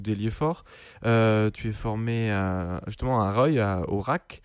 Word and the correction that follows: Rueil: On dit Rueil, excuse-moi. – Vas-y. – On dit Rueil. – Rueil, pardon. Ah oui. Oreilles, mais... c'est vrai que j'ai mal prononcé.